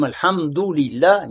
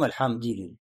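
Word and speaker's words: аль-хамду-лилля (الحمد لله): Мы говорим аль-хамду-лилля, не говорим аль-хамди-лилля. Правильно говорим аль-хамду-лилля.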